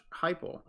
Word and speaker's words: Heupel: Like the last two teams, they have a new head coach as well, and Josh Heupel. I think that's how you say it, H-E-U-P-E-L.